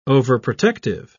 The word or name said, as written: overprotective